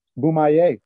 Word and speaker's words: Bomaye: I'm gonna butcher it. – Boomaye. – Damn it! I was getting ready to say it, man. Boomaye. Sure.